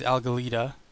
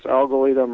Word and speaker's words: Algalita: Your organization is Algalita.